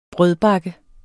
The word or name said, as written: BAKKER